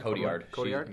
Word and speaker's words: Cotillard: Cotillard.